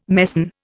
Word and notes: mesne: Indian

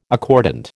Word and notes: accordant: American English

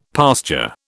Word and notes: pasture: British English

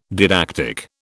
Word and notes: didactic: British English

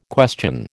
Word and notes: question: American English

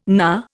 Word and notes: knar: Indian

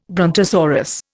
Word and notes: brontosaurus: Indian

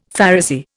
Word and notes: Pharisee: Indian

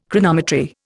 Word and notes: Chronometry: Indian

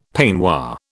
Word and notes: peignoir: British English

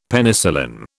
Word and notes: Penicillin: British English

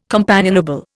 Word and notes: companionable: Indian

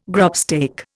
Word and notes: grubstake: Indian

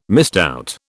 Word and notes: misdoubt: British English

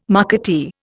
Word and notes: marketeer: Indian

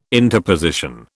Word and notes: interposition: British English

